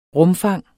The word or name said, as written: rumfang